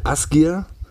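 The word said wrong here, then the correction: Ásgeir: Asgeier, Asgeier?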